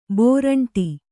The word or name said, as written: ಭೂರುಂಡಿ